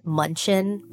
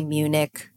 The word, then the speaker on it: Munich: I said that the shade for the Monica Blunder blush that I was speaking about was Munchen, but it's actually Munich. It was just written in, you know, German. Like, you're so cute, but that's like not how you say it. It's Munich.